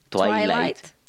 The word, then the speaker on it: Twilight: no, así no se pronuncia. ¿Es Twilight? – Twilight.